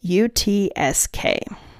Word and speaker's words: Yakutsk: It is Yakutsk.